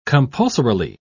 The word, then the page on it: compulsorily: British English